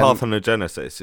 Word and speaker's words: parthenogenesis: called um, parthenogenesis, but I may have said that wrong. – Um, now, parthenogenesis is correct.